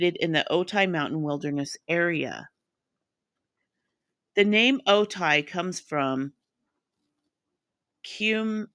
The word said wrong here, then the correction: Kumeyaay: And I know I just pronounced that. I just butchered that. I'm sorry. It's Kumeyaay.